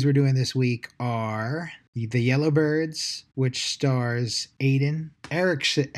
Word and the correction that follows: Alden Ehrenreich: Alden Ehrenreich. – That's it. Yeah. Thank you.